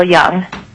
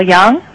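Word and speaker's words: Yeung: Chairman Ledoux, that's correct. It's Rochelle Young.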